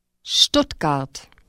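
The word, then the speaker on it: Stuttgart: Сочетание звуков С, Т просто добавляет Т к звуку H. – Шт. Штутгарт.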